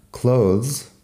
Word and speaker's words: clothes: Si lo pones en plural, clothes, la pronunciación cambia un poco y llega a ser ropa.